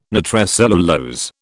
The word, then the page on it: nitrocellulose: British English